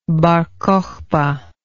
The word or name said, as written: bar cocheba